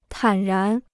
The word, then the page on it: 坦然: tǎn rán